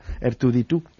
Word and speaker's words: R2-D2: R2-D2, en inglés.